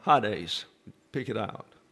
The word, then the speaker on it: Hades: And the Ada, there are two E's in Greek, and the Ada is pronounced like a long A. So you see the E there, but you pronounce it as if it were a long A, Hades, and the accent's on the first syllable.